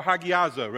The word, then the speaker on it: hagiazo: Sanctification is the Greek word hagazo. Or hagiazo, rather, mispronounce it.